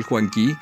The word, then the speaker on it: Juanquí: Mi nombre es Joel Juanquí. Mis redes sociales en Facebook, Instagram y Twitter son @joeljuanqui, así como se escribe, Q-U-I.